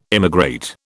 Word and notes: immigrate: British English

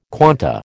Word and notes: quanta: American English

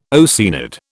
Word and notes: Oceanid: British English

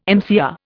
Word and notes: MCR: Indian

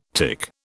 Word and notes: tic: British English

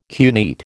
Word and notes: cuneate: American English

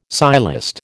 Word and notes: sciolist: American English

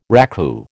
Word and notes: raku: American English

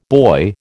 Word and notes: boy: American English

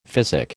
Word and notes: physic: American English

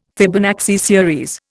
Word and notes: Fibonacci series: Indian

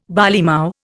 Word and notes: barleymow: Indian

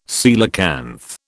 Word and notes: coelacanth: British English

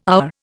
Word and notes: hour: Indian

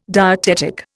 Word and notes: dietetic: Indian

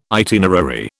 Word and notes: itinerary: British English

